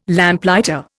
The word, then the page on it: Lamplighter: Indian